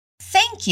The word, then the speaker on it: thank: Thank you significa obrigado ou obrigada. Repeat. Thank you. Foca na pronúncia do TH e tenta replicar. A sua língua tem que estar entre os dentes.